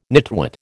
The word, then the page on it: nitwit: American English